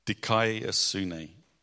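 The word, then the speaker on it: Dikai asune (δικαιοσύνη): How do you say that, Greg? Dikai asune.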